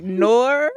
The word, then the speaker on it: noir: Like, Noir. – Like black in like French.